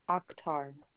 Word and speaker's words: Akhtar: Akhtar.